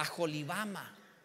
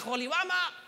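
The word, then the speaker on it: Aholibama: Esaú tomó sus mujeres de las hijas de Canaán, a Ada, hija de Elón, Eteo, y a, las, las, H bíblicas son como J, ¿eh? En realidad se debería pronunciar A Jolibama Santo Dios. Imagínate nada más. Jolibama Ya llegué a Jolibama.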